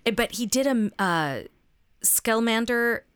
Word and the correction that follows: Scamander: Newt Scamander.